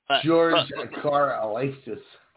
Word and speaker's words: Karlaftis: But I'd rather see that at 12 than Karlaftis. Did I say that right? – Yeah, there you go. Karlaftis.